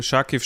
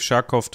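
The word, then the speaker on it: Charkiw: Ja, also äh, Scharkiv, Scharkow, das sind einfach zwei unterschiedliche Aussprachen. Ähm, äh, Scharkow ist eher russisch und ähm, Scharkiv ist eher ukrainisch.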